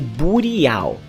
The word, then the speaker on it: burial: Se escreve burial, mas se lê burial, ok?